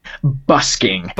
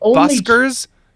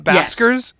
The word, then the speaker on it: busking: They've stopped basking in Ashland? – Busking. – Busking. Have I been fucking saying this wrong my entire life? Is it buskers, not baskers? – Yes.